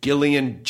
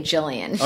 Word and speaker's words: Gillian: As like Gillian Jacobs. That is the correct hard G, soft J.